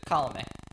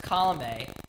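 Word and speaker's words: Colomé: Colome. – Yeah. Yeah. Pronunciation's not my skill. – So, interestingly enough, though, it's Colome. And, Adam, you made a slight mistake earlier, which I thought it was this for a long time.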